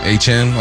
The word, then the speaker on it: Achane: And you got, how do you say, not Etienne, Achane? – Achane over there.